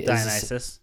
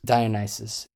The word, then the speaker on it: Dionysus: He stars in Brooklyn Nine-Nine, and he plays, I can never pronounce it, but Mr. D, Dionysus or Dionysus, and he Dionysus. – Dionysus.